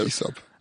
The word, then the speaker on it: Aesop: Yeah, Aesop.